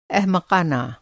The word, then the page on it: एहमक़ाना: احمقانہ